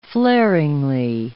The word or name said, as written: flaringly